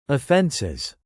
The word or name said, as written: offences